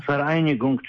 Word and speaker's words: Vereinigung: Vereinigung, czyli zjednoczenie na przykład. Czyli to jest V, u nich V to jest wymawiane jako F.